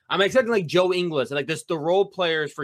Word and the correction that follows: Ingles: Ingles. He's an Australian. Say his name. – Ingles. – Right. – Sorry, Ingles.